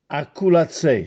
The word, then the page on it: Acculazzè: Castellanetano